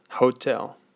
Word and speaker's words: hotel: Hotel, hotel. Remember, the H in Spanish is silent, so you don't pronounce it.